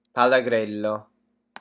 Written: pallagrello

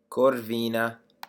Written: corvina

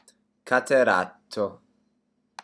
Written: cataratto

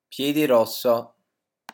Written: piedrosso

